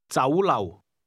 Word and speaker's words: 酒楼: 楼, 酒, 酒楼, 酒楼. – The Cantonese word restaurant is pronounced with a combination of mid-rising and low-level tones.